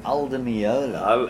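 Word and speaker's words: Al Di Meola: Aldi Muli, I'm pronouncing it right. – Aldi Meola. – Aldi Meola, your favorite guitarist.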